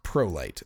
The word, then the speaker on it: Prolycht: a German sounding spelling of a name, but it's pronounced Prolight, Prolycht.